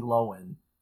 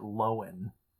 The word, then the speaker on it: Lohan: Or yeah, I think like Lindsay Lohan recently was like, it's actually Lindsay Lohan. She was like, everyone in my family pronounces it Lohan.